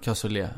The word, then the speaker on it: cassoulet: Uttalas det cassoulet? – Cassou, ja, cassou, jag kan inte franska men mm. cassoulet, ja.